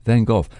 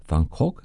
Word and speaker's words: van Gogh: Now, there are various pronunciations. The Dutch pronunciation sounds a bit like Van Gogh, but the English version sounds more like Van Gogh or Van Gogh. Take your choice.